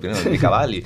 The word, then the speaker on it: Machiavelli: Bueno, Machiavelli, ¿cómo se dice en español? – Machiavelli. – Déjame decirlo en italiano, Machiavelli.